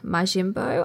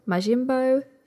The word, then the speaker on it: Majimbo: Okay, I don't know how to pronounce Elsa's surname, but Elsa Majimbo, I would say, is probably the right pronunciation. But her account is MAJIMB full stop O. Majimbo, she's funny.